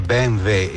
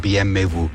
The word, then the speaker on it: BMW: Volevo fare i complimenti a Meazza per la perfetta pronuncia, si dice BMW e non BMW, come si dice Mercedes e non Mercedes o Mercede. – Caspita, stamattina che bel complimento che hai ricevuto. – Guarda, ci aggiungo che non lo dice nessuno, però si direbbe BMW.